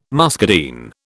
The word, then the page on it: muscadine: British English